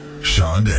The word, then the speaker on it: Sade: name is spelled S-A-D-E, but you call her Shawnde.